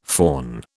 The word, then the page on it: föhn: British English